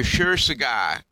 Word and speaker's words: Kshirsagar: We have Dr. Shir Sagar, S-H-I-R-S-A-G-A-R is how it would be pronounced, but that isn't how it's spelled. It's got a K in front of it. Dr. K, S-H-I-R-S-A-G-A-R, who's a physician who has written a really wonderful book called Change Your Schedule, Change Your Life.